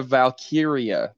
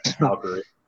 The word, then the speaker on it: Valkyria: Lyra Valkyria, my guy. – Valkyria, is that what it is? I thought it was Valkyrie. – I wrote it down as Valkyrie. – Well, that would be why you mispronounced it.